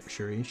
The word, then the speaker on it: Shuri: So he needed to find that person, and with Shuri, however you want to pronounce it, it's Shuri, wanted to protect the girl who is Ironheart, who is very intelligent, she's very intelligent.